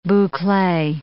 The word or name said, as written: boucle